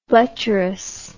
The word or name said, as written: bletcherous